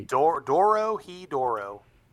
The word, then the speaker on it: Dorohedoro: Doro he Doro. – That's right. And it's it's actually it's actually spelled phonetically D O R O H E D O R O.